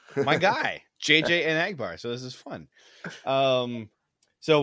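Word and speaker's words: Enagbare: He told us how to pronounce his name. It's Anagbar. Kingsley oh. Anagbar.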